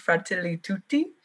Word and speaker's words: Fratelli Tutti: Like, sorry, we did like a little mini a session or a couple mini sessions, and we talked about Francis's encyclical, the, I'm going to say it wrong, cause I want to say like Ratatouille, and I know that's not what it is. It's Fratelli Tutti.